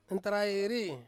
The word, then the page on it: nentarai ri: Ansus